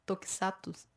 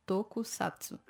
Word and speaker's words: Tokusatsu: Tokusatsu. – Tokusatsu.